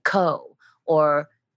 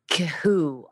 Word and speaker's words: Khoo: And like K-H-O-O is pronounced Koo. Some people pronounce it Ko or Kahoo.